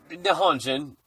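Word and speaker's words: Nihonjin (日本人): And they are told that this is I believe it's pronounced Nihonjin. Nihonjin? I don't know if I'm saying that right. – Nihonjin.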